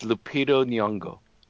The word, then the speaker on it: Lupita Nyong'o: Did I say that right? By the way, say her name for me. – It's Lupita Nyong'o.